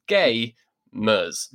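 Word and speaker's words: Gaymers: I should also specify to listeners, when we say Gamers Inc, it's it is for gamers, but it's spelt G-A-Y as in gay mers.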